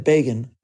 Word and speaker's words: Begin: But it is more appropriate to say, as Time magazine could so easily have written, that Begin rhymes with Reagan.